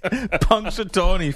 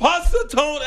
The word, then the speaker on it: Punxsutawney: Every Groundhog Day, Pustacula Pocahontas comes out. And with a papoose. – It's Punxsutawney Phil. – Punxsutawney. I was close. Punxsutawney Phil.